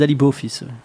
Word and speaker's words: alibofis: Comment ça se prononce? – Alibofis. – Alibofis avec un F-I-S.